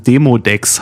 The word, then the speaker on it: Demodex: Ich habe es falsch ausgesprochen. Demodex heißt es.